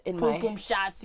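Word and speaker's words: pum pum shorts: I showed up and she hopped in the whip in a tank top and poom poom shorts. – Poom poom shots. You got to pronounce it properly.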